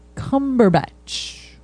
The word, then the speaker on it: Cumberbatch: It's Benedict Cumberpatch. – Oh. – No, you're saying it wrong. You said patch. It's batch. – Benedict Cumberbatch.